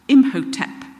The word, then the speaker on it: Imhotep: Imhotep, that's how you pronounce it, who was supposed to be the god of medicine.